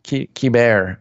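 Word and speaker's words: Keibert: Kybert. I don't even think pronounce the T.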